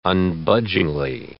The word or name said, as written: unbudgingly